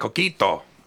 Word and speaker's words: Coquito: You gotta say it like Coquito. Not like my girlfriend was like, Coquito. And I was like, you are so white. And that's coming from me. It's Coquito.